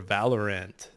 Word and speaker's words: Valorant: So the nominees are Counter-Strike Global Offensive, Dota 2, League of Legends, Rocket League, or Valorant. I said it right that time, you fuck.